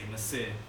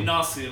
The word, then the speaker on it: Nasir: Like, a lot of people, they can't pronounce my name, so I'd say Nasir. It's meant to be Nasir.